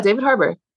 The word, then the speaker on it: Harbour: Yeah, David Harbour.